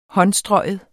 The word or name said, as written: håndstrøgen